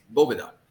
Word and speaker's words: Boveda: It's not Boveda, it's Boveda. And Carlito Fuente actually had to tell our founder, Sean and Tim, how to correctly pronounce the name of Boveda, which I think is absolutely hilarious.